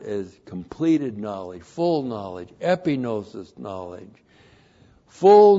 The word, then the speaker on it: epignosis: Epignosis, E-P-I-G-N, long O-S-I-S.